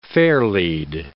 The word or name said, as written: fairlead